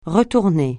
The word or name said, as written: retourner